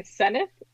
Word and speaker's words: Senft: Senft? It kind of sounds like there's an I between the N and the S.